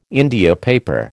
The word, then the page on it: India paper: American English